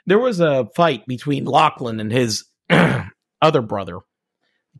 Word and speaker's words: Lachlan: Lachlan.